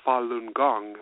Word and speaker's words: Falun Gong: And I came across Falun Gong,